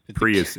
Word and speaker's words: Prius: Like a Prius or Prius for Australia, for Americans. Prius, if you pronounce it properly.